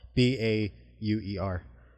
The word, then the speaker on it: Bauer: B a u e r.